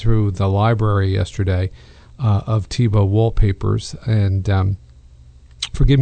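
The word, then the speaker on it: Thibaut: It's spelled T-H-I-A-U-B-T. It's an unusual spelling, pronounced Tebow.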